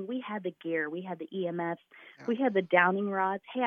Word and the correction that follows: dowsing: So the dowsing rods.